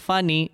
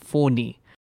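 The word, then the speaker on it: Fani: Yes, guys, it's not pronounced funny, it's pronounced Phoni.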